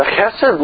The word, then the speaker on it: chesed: The chesed love, God's love, and yes, it has that rough sort of sound at the beginning, that's how Hebrew kind of works, is the word that is used throughout the Psalms.